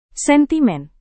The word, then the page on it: sentimen: indonesian